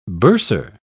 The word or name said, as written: bursar